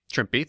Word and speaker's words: Trimpe: Len Wein just, he co-created Wolverine in 1974 with Roy Thomas and artists John Romita Sr. and Herb Trimpe, or Trimpy, not sure what the pronunciation is. – Trimpy, thank you.